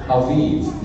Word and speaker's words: Aviv: It's spelled with a B, but you say it with a V. So in the month of Aviv, which will be our March or April.